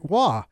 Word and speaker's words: Roy: It's tough, but it looks every bit like it would be Roy. – Oh, absolutely. But it's not. Absolutely. That French Canadian pronunciation.